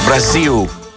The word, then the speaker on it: Brasil: Ακουγόταν αυτή η μουσική που λεγόταν ο τίτλος Viva Brazil, Brasil, με προφορά.